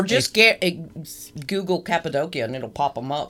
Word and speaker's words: Cappadocia: The world's largest underground city was recently discovered in Cappadocia, Turkey. – Oh, really? – Yes, and I know I'm saying that right because I've been there.